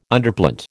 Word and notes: Underplant: American English

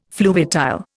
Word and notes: fluviatile: Indian

